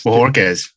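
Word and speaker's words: Bojorquez: Bajorquez. – Bajorquez. That's what I thought. – A lot of people get confused with the Kez. They want to say Quez.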